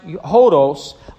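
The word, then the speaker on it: hodos (ὁδός): It is a compound of the words meta and odos, pronounced hodos.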